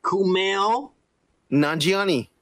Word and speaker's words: Kumail Nanjiani: And I'm doing the fucking American accent, by the way. – All right, Kumail Nanjiani. I said it right. – The real accent would be like Kumail Nanjiani.